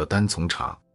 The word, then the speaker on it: 单丛: 不过相信多数旅行者最开始见到的单丛茶，其写法大多是单丛茶。在潮汕话的语境里，丛为量词，意为科，读作散，如一丛树。